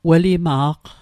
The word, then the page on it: welima'q: Mi'gmaq